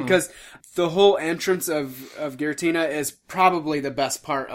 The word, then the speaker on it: Giratina: And then, you know, that doesn't last very long, because Giratina, I don't know how to say it, Garantia. – Giratina? – Giratina.